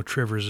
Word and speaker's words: Two Rivers: If you're from Wisconsin, you know Trivers is two rivers, but that's how you pronounce it.